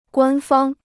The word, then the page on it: 官方: guān fāng